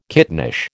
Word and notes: kittenish: American English

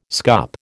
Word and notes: scup: American English

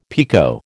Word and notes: picot: American English

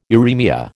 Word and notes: uraemia: American English

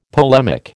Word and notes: polemic: American English

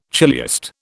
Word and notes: chiliast: British English

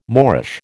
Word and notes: moreish: American English

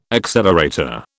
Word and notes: accelerator: British English

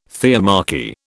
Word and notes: theomachy: British English

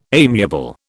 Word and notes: amiable: British English

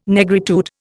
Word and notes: Negritude: Indian

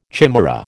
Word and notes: chimaera: American English

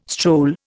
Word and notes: stroll: Indian

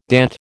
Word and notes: DAT: American English

